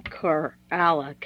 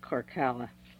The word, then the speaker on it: Korala: And his name is actually Corala, K-O-R-A-L-A, not Corcala. There's not a double K in there.